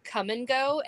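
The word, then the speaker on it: Kum & Go: But it's from a place called Come and Go, and it's spelled K-O-M. – I love Come and Go. – No, K-U-M. – K-U-M. – K-U-M.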